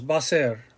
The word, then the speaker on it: va a ser: As a teacher, and we pronounce things out, sometimes we have a tendency to slow down the pronunciation, va a ser. But when you pronounce it, it's like two syllables, va ser, va ser.